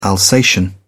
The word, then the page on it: alsatian: British English